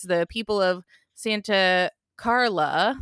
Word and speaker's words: Santa Carla: I also appreciate you pronouncing it properly as Santa Carla, because the mayor of Santa Cruz didn't want his town depicted.